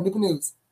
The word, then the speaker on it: Newport News: And you said it right. Thank you. It's one word, Newport News.